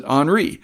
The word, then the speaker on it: Henri: That's spelled Henry with an I, but you pronounce it Henri.